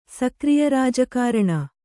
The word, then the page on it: ಸಕ್ರಿಯರಾಜಕಾರಣ: sakriya rāja kāraṇa